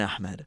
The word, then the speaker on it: Ahmed: There's no kha People okay. always say Ahmed as if they're like being extra like, oh no, let me pronounce it. Okay, right. Ahmed. It's like there's no ha in Ahmed. It's a ha.